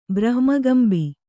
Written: ಬ್ರಹ್ಮಕಂಬಿ

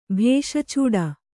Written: ಭೇಶಚೂಡ

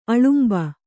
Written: ಅಳಂಬು

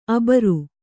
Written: ಅಬರು

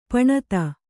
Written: ಪಣತಿ

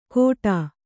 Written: ಘೋಟಾ

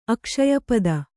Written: ಅಕ್ಷಯಪದ

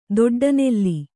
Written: ದೊಡ್ಡನೂಲು

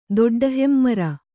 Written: ದೊಡ್ಡಹೆಮ್ಮರ

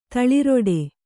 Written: ತಳಿರಡಿ

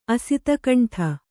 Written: ಅಸಿತಕಂಠ

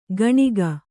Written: ಕಣಿಕ

